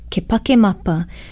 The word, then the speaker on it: kepakemapa: Aloha, olelani poli ahu keia, and our Hawaiian word for today is another borrowed word, kepakemapa. Kepakemapa is spelled K-E-P-A-K-E-M-A-P-A, and yes, it's the Hawaiian word for September.